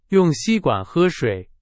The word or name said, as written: 吸管